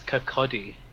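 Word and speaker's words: Kirkcaldy: But it's not even Kirkledy; it's Kirkody.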